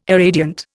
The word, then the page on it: irradiant: Indian